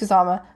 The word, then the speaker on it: Kusama: I always mispronounce her name. The director of Jennifer's Body and The Invitation. I think it's Kusama.